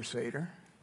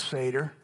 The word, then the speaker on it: Seder: Obviously, a lot of us have never celebrated a Passover Seder. It's spelled S-E-D-E-R, Seder.